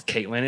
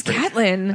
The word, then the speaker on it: Catelyn: Instead of Catelyn, it's Caitlyn. It's just, it's very, very. – No, it's Catelyn.